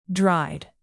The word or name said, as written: dried